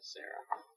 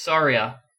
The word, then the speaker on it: Saria: I always said Sarah. Because Sarah is easy to say, instead of Sarariar. – It's Saria.